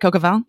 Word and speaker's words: coq au vin: And so one of the reasons I went all the way out to Jersey is because my friends were making coq au vin. Is that how you say it? Coq au vin? – Yeah, it's coq au vin.